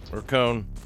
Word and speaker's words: Kone: Kone, spelt with a K. So my default instinct is to go with the Finnish pronunciation of Kone, which means machine, which is appropriately appropriate since it's the name of a Finnish company that makes escalators. Kone.